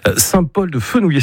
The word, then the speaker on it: Saint-Paul-de-Fenouillet: Perpignan, vous irez un petit peu plus tard parce que c'est un peu la grande ville pour vous, mais Saint-Paul-de-Fenouillet, c'est comme ça que vous le prononcez. – Ouais, c'est ça.